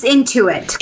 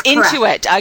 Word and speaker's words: Intuit: She started her first company in 1997, which was later sold in 2005 to Inuit. Is that how you pronounce it? Inuit? – It's Intuit. – Correct. Intuit.